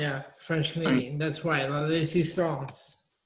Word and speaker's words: La Résistance: You see how I said that? La Résistance. – Well, you are a Canadian, so I guess. And you know French, so you would be able to pronounce that properly.